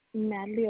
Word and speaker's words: Natalie: Natalie Orozco. – Spell it for me. – N-A-T-A-L-I-E. Last name O-R-O-Z.